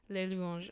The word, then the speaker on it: Les Louanges: You don't pronounce the plural in French. – I forgot. I thought there was words. I thought it was like, whatever. Anyway, Les Louanges, but he's like a French-Canadian dude.